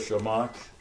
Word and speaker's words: Shammash: The ninth candle is called I'll spell it Shamash, S-H-A-M-M-A-S-H, or servant candle.